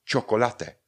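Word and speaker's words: chocolate: Další příklad, třeba čokolate. Úplně stejný problém. Zase máme CO uprostřed, čokolate. To vyslovíme jako ko, no a ch máme na začátku, které vyslovíme jako č. Čokolate.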